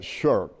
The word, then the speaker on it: shirk: Now, I would pronounce that shirk.